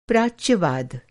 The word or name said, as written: प्राच्यवाद